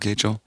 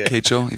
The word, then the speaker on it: Kachel: I'm Joel Kachel. – Spelled like Rachel? – Yeah, my last name is Rachel, like Kachel. If you erase the top of the R, you get Kachel.